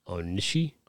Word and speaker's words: Onishi: K-A-I-T-O. Yeah. Okay, Kato makes sense. – O-N-I-S-H-I. – O-N-I-S-H-I. Onishii.